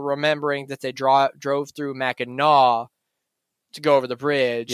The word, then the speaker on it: Mackinac: I did a pronunciation on an app. I spelled it out, and you heard it in that episode. And it's Mackinac. – Well, yeah, you look at how it's spelled, there is no really disputing it. Because like I said, there is no language that I know of that pronounces the NAC as gnaw.